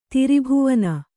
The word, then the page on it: ತ್ರಿಭುವನ: tri bhuvana